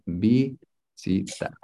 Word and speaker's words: visitar: Okay, so technically, it's visitar. – Oh. Ooh. – So the V in Castilian Spanish, in 90% of Spanish-speaking countries, the V is pronounced B.